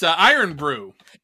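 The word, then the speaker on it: Irn-Bru: It's Iron Brew.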